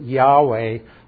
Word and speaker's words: Yahweh: We pronounce it Yahweh.